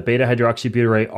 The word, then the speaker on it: beta-hydroxybutyrate: the beta-hydroxybutyrate R13-butanediol ketone ester, that's going to be utilized differently by the mitochondria? – Yeah. So, sorry, you said that right.